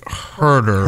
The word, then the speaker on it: Huerter: Huerter? Kevin Huerter? – I think Huerter.